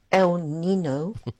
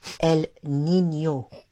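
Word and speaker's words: El Niño: Not pronounced El Nino, but El Nino. Just like El Nina.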